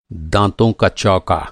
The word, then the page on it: चौका: چوکا